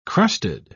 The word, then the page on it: crusted: British English